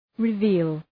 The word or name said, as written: reveal